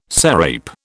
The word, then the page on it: sarape: British English